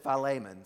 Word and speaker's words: Philemon: This is probably, I don't hear about it very often in churches, but Paul's last letter, the shortest letter that he writes, it's called Philemon, or Philemon, or Philemon, whatever you guys pronounce it as. I've heard it like five different pronunciations. My Greek professor said Philemon, so that's how I pronounce it.